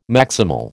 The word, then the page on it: maximal: American English